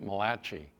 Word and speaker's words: Malachi: Fred pronounces it Malachi. That's how they do it in Italy, he tells me.